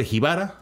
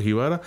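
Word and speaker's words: Gibara: y mejor película en el Festival del Cine Pobre Jibara, así se pronuncia, Pobre Jibara, en Cuba.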